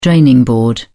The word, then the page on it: draining board: British English